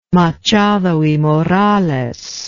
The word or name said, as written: machado y morales